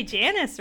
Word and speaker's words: Janus: I'm like, is it pronounced Giannis or is it Janus? – It's got to be Janus, right? January, Janus.